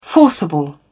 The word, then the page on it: forcible: British English